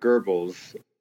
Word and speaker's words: Goebbels: Joseph Goebbels, with a slight R pronunciation, was the Minister of Propaganda.